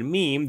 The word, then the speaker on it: meme: So he just details how he came up with the word meme that he was trying to make rhyme with jeans and cream.